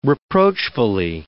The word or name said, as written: reproachfully